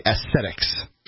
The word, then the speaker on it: aesthetic: aesthetics. – But you didn't pronounce aesthetic correctly, even though I thought I knew what you were saying. I said, do you mean aesthetic? – And you hear what you said.